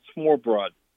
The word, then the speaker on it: smørrebrød: I'll spell it first. It's S M O R R E, and then B R O D. I think it's pronounced smørrebrød.